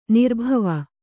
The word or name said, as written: ನಿರ್ಭಾವಿ